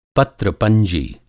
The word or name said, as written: पत्र पंजी